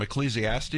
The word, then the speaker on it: Ecclesiastes: And that is from Ecclesiastes.